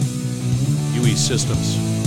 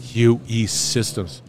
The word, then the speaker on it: UE Systems: UE Systems. Did I say that right? – You did. – As if I can't spell U. – UE. Yeah, it's the letters E. U and E. – UE Systems.